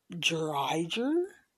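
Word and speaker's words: Driedger: They have already announced that they are starting, I believe, his last name is pronounced Dreiger.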